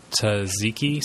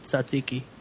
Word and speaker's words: tzatziki: But then I put some tzatziki sauce. What's that? What's that Greek? – Tzatziki. That's the good stuff. – Pronounce that. – I said it tzatziki. – Tzatziki? – Tzatziki.